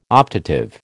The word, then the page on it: optative: American English